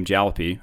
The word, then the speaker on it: Jalopy: And so your first game, your first indie game, Jalopy.